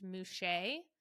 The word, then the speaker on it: muxe: They have male, female, and then they have a gender that's spelled M U X E, which is pronounced mouche.